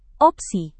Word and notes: opsi: Indonesian

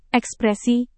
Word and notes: ekspresi: indonesian

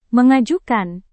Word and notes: mengajukan: Indonesian

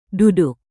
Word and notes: duduk: indonesian

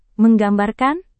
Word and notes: menggambarkan: Indonesian

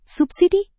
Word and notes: subsidi: Indonesian

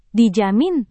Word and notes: dijamin: Indonesian

